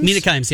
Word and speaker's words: Kimes: And good for the Mets, who up until the story, was it Mina, how do you say her name? Mina Kimes? – Mina Kimes, yeah.